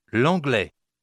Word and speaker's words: l'anglais: L'anglais. L'anglais. – How do you say English? – L'anglais. – Now say the same word, leaving off the L sound at the beginning. – Anglais.